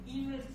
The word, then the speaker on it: Eaves: Qui est, Mrs. Yves Yves, Yves s'écrit E-A-V-E-S. Yves, donc, est-ce qu'elle a servi Alors, c'est pas sa voix.